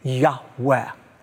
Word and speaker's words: Yahweh: In today's Hebrew Moments, I want to focus on God's covenant name, which we believe is pronounced a breathy Yahweh.